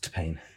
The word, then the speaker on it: T-Pain: It's pronounced T-Pain. There's no emphasis on the T. It's T-Pain.